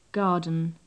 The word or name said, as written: garden